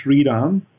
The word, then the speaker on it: Shriram: My name is Shriram.